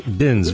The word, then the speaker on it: Binz: I see it's spelled B-I-N-Z. – That's right, Binns. – Binns, very good.